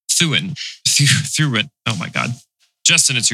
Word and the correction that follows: Thurin: No, it's pronounced Thurin.